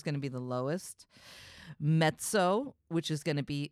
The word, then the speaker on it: mezzo: Also, you, you, when you guys see it, it's written as mezzo, but it's like pizza. This is the way you pronounce it in Italian, mezzo. So just realize that's what it is, mezzo.